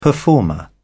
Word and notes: performer: British English

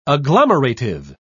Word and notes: agglomerative: British English

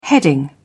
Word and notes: heading: British English

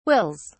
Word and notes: wills: British English

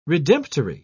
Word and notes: redemptory: British English

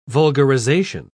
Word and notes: vulgarisation: British English

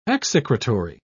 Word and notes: execratory: British English

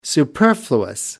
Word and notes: superfluous: American English

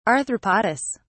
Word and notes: arthropodous: British English

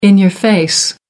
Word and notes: in-your-face: American English

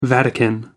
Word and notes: vatican: American English

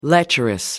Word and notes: lecherous: American English

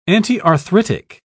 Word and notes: antiarthritic: British English

